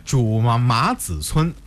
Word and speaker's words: 朱麻子村: 那看来我这个读音没有错哈，这个名字应该是朱麻子村啊，这个如果换成现在这个姓的话，应该是朱麻子，也是这个意思，对不对？